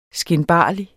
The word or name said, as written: skinbarlig